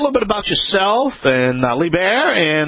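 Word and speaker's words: Liebherr: You're close. It's LeBaire, but it depends on if you ask an American or a German. But if you stick with LeBaire, you're pretty close.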